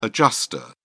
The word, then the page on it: adjuster: British English